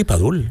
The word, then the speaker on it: Padul: Sí, sí, Padul, en Padul, Granada.